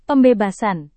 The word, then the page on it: pembebasan: Indonesian